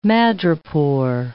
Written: madrepore

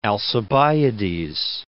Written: Alcibiades

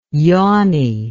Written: yawny

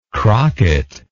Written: crocket

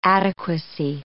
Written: adequacy

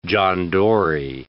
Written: John Dory

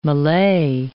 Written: Malay

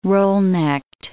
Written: roll-necked

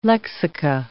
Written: lexica

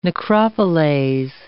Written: necropoleis

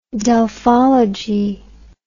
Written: delphology

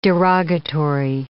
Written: derogatory

